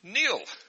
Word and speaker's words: Neil: And my name is Neil.